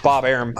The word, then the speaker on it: Arum: Yeah, I would say it rhymes with Bob Arum. It's Bob Arum.